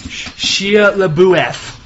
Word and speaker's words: Shia LaBeouf: His name is pronounced Shia LaBeouf.